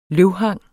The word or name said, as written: løvhang